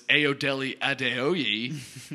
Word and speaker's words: Ayodele Adeoye: And also on the field is Ayodele Adeoye. I know I totally butchered that name. – That sounded good, actually.